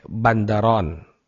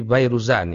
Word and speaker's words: Al-Bayruzan: Adapun di belakang, ya, di belakang ada pasukan cadangan yang dipimpin oleh Bandaron, ya. Atau ini kalau dihapus titiknya juga bisa dibaca Bayruzan, Al-Bayruzan, Fairuz, ya. Bukan Bandaron tapi Bayruzan, ini yang lebih tepat saya kira bacanya.